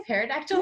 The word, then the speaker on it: pterodactyl: It's something about pterodactyl, and it says something like the punchline's like, because it has pee in it or something. Because the pee is silent.